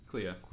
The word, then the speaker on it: Clea: Clea. – Clea. Did I get that? I actually got the name right for once. I almost said Clara.